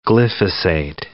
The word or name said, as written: glyphosate